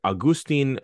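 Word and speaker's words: Agustín: One really important leader in this effort was a man named Augustin. Uh, I'm sorry, Agustin uh, Farabundo Martí Rodriguez, or Farabundo Martí for short, or Martí.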